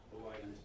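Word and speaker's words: Hawaii (Hawaiʻi): Now I grew up, we used in Hawaii, the letter W is pronounced with a V, Hawaii.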